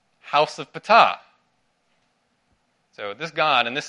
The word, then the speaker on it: Ptah: The third section is all about the god of Memphis, Ptah. Yes, that's actually how you pronounce it. I looked it up. Ptah.